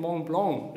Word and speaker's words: Mont Blanc: Ich wollte auch mal auf einen großen Berg gehen, nämlich auf den Mont Blanc, richtig ausgesprochen.